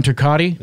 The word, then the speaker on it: Turcotte: I think it's Ron Turcotte. I don't know how to pronounce his last name. Ron Turcotte. – Ron Turcotte. – Is that right? – Wow.